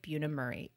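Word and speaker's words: Bunim Murray: Am I saying that right? Is it Buna Murray? – That's how I would say okay.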